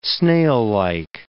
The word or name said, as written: snaillike